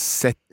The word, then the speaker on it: cette: It becomes set. – Set. Okay, it sounds like number seven, but it's spelt. – C-E-T-T-E.